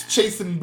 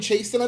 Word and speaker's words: Chasten: oh, um, is his name Chasen? I think it's it's Ch- it's C H A S T E N. I have not heard it pronounced out loud. – Chasten, Chasten. I think it's probably Chasten.